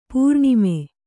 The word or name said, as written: ಪೋರಾಣ್ಮ